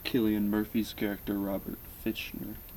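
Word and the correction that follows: Fischer: It's pronounced Fisher.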